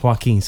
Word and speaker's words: Joaquin: Joaquin, sorry.